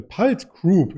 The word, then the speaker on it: PulteGroup: Und da gibt es einen Player in dem Bereich, der heißt äh, Palt Group.